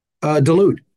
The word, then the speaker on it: DeLude: And I'm here with somebody that I've gotten to know over the last couple of years, Robert DeLude.